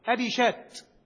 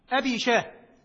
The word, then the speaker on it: أبي شاه: أبي شاه بعضهم ينطقها بالتاء المربوطة أبي شات، والصواب والراجح أنها بالهاء أبي شاه.